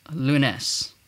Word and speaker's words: lunes: Okay, so let's linger on to the next one with Monday, and Monday in Tagalog is lunes, lunes, lunes, lunes, lunes. So it's emphasis lunes. So I keep going in the beginning and then faster in the second syllable.